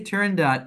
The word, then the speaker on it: Turandot: Am I saying that right? Turandot? – Oh, great question. I say Turandot, and that's what most people in the opera world say, but some people say Turandot, and Turandot.